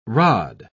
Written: rod

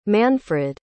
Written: manfrid

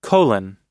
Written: colon

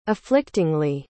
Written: afflictingly

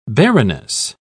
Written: barrenness

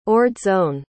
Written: ordsone

